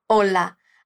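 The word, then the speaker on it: hola: A palavra que utilizam é o clássico olá. Aqui eu quero te chamar a atenção para a diferença de pronúncia. Em português, a gente enfatiza o lá, olá, mas em espanhol a ênfase está no o.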